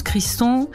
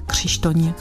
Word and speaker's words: Krzysztoń: Alors mon nom en France en général se prononce Kriston. Et puisqu'on est en Tchéquie et que c'est à côté de la Pologne et que la prononciation se ressemble beaucoup, je préfère en général ici qu'on me dise Krzysztoń.